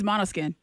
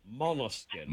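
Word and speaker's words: Måneskin: So, we're going to see the name of the band is spelled M-A-N-E-S-K-I-N. What's the name of the band? – It's Monoskin. – Monoskin. – Monoskin. That's correct.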